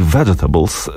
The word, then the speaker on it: Vegetables: No, oryginalnie w 1980 roku Jello Biafra zaśpiewał Let's Lynch the Landlord na płycie Fresh Fruit for Rotting Vegetables. Nie wiem, czy dobrze wymawiam. Świetnie. Bardzo fachowo vegetables.